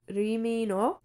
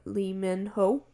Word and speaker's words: Lee Min-ho: Okay, so, Ri Min-ho. I'm pretty sure that's how you say his name, like, in Korean accent, but Lee Min-ho.